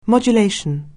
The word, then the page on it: modulation: anglais